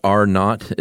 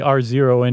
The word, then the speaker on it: R naught: Yeah, and that reproduction number, in the biz, it's pronounced R naught. It's R with a zero, huh? – Yeah. I'd rather say R0 any day of the week.